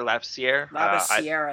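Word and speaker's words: Labissière: Other guys that people talk about, Scal, Labissiere. – Labissiere, I think it's pronounced.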